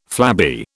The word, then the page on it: flabby: British English